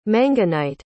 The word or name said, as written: manganite